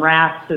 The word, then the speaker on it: Raths: Chaplain Raths is kind of like being called Dr. Payne, having the last name.